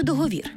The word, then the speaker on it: договір: Договір чи договір? В словниках Грінченка і Голоскевича саме договір. Та й договірний там таки.